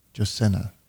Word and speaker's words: Jacinda: Somebody's asking, am I saying her name right? Jacinda? Jacinda. There we go.